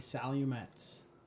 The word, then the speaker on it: Salumets: Twitter handle is A Salumets.